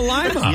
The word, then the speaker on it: Lima: It's pronounced differently. – Oh, yeah. There you go. That's it. – Lima Lima.